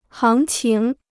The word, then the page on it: 行情: háng qíng